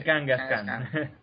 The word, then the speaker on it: Kangaskhan: Cubone y Kangaskhan.